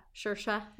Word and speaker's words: Saoirse: Saoirse rhymes with inertia.